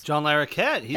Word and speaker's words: John Larroquette: John Larroquette.